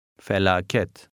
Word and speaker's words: felaket: The E in the ending has an E sound. – Felaket.